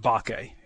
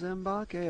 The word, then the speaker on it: Mbake: Also, I just want to clarify, it's just Bake. You just drop the M. – Oh, really? – The M is silent. Just Bake. – I thought it was Mbake.